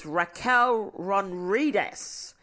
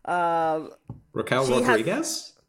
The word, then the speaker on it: Raquel Rodriguez: We had our first out of our three returnees at this pay-per-view, which was Raquel Rodriguez. I have said it wrong. I'm fully aware I have said it wrong, but fuck it. – Raquel Rodriguez? – Yes, but you know I can't say words.